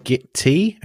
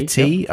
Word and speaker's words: Gitea: I have one on my LAN, which is Git-T. It's spelled G-I-T-E-A. So Gitea. – Oh, yeah, like a cup of tea. – Git-T.